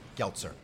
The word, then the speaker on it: Geltzer: Geltzer. – Geltzer, thank you. Rhymes with seltzer.